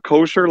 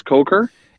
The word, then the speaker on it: Kocher: Phil Kocher. – Is it Kocher? It's not. It's not kosher like a hot dog. It's Kocher.